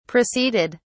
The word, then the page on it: proceeded: British English